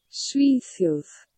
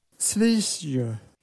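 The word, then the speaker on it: Svíþjóð: Så här uttalas det. – Svitjod. – Svitjod.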